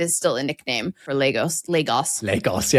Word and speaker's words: Lagos: Or Lagos is typically how it's pronounced.